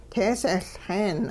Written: teesehLxeen

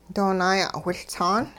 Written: doo naaya'wiLtsaan

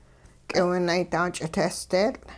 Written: k'iwunaydaa-ch'iteesdeetL'

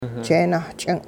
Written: jeenah-ch'ing'

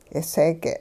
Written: k'iseege'

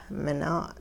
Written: minaa'

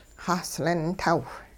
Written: xahslin-taw

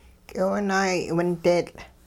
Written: k'iwunaayay'windeetL'

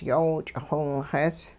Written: yoow ch'ixiwinxees

